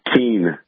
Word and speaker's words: Keene: Hey, listen, you know, I'm looking at a map of, you pronounce it Keene, New Hampshire, is that correct? – Yeah, that's right.